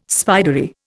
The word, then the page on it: spidery: Indian